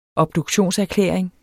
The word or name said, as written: obduktionserklæring